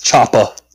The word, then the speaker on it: chopper: Well, you said you gotta leave the ah out of there. It's chopper.